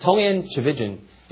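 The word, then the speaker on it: Tchividjian: Tolian Chavijan, I finally learned how to say his name. He says it rhymes with division, so but it's spelled, has a lot more consonants than vowels, kind of.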